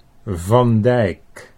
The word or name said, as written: Van Dijk